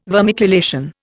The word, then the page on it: vermiculation: Indian